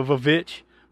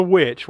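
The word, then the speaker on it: VVitch: So this movie is also known as, you know, without being funny, the Vavitch. But it's the witch, right? But it's just spelt with two V's.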